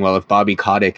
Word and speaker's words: Kotick: but Bobby Kotick, is that how you pronounce it? – Yep.